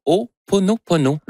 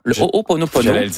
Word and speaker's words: Ho'oponopono: Non, c'est une philosophie hawaïenne de, je vais le prononcer comme il faut, de Ho'oponopono, Ho'oponopono, qui est une philosophie ancestrale qui sert à régler les conflits, à lâcher prise, à modérer nos attentes et à rétablir sa paix intérieure. Donc, Ho'oponopono.